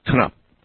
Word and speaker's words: Knupp: Knupp. That's the way you say it. Actually pronounce the K, I guess. Knupp.